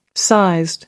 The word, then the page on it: sized: American English